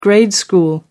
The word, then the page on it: grade school: American English